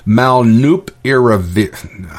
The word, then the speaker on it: molnupiravir: The drug itself is called molnupiravir. I can't even pronounce it. Molna. It's M-O-L-N-U-P. Molnup. I-R-A-V-I-R.